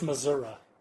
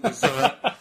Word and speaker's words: Missouri: They did win that very exciting series against Texas A&M, but then the next weekend they go to Missouri and get swept. – I believe it's pronounced Missouri. – Excuse me, Missouri.